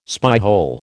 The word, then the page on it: spyhole: American English